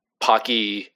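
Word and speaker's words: Paqui: And I made sure to check the Pocky YouTube channel before we started recording just to make sure we were saying it right.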